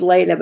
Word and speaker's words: Leila: It's pronounced Layla, but it's spelled L-E-I-L-A, right.